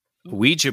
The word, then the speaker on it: Ouija: A Ouija board? – Ouija board.